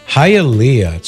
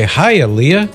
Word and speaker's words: Hialeah: Hialeah. So if you know a girl named Leah, you'd say, Hialeah.